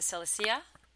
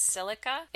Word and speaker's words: silica: And some people pronounce it silicea, but it's silica.